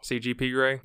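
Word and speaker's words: CGP Grey: And there's also a good CPG Gray video about it. I did say it right that time, right? – No. – CGP Gray? – Yes.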